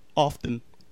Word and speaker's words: often: Often. – You know you don't pronounce the T in often.